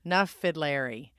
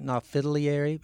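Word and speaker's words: Na Fidléirí: Na Fidlieri. – Na Fidlieri. – That's it. People try to get the word fiddle in there. Na Fidlieri, it's just Fidlieri.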